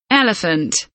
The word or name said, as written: elephant